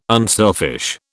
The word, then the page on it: unselfish: British English